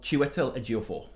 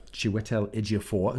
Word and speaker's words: Chiwetel Ejiofor: Chiwetel Ejiofor. – Chiwetel Ejiofor, who's both very, very good actors.